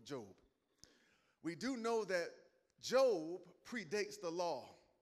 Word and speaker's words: Job: It's spelled like Job, but it's pronounced Job.